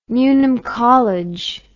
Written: newnham college

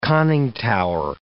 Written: conning tower

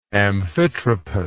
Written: amphitropous

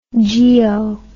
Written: geo

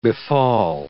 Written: befall

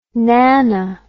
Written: nanno

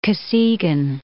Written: Kosygin

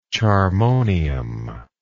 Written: charmonium

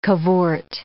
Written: cavort

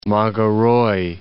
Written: Mageroy

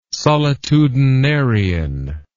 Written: solitudinarian

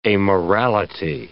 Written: amorality

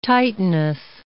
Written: titaness

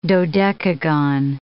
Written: dodecagon

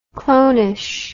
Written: clonish

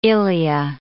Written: ilea

